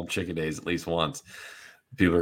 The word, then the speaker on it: Chikadze: Cub Swanson versus Giga Chikadze.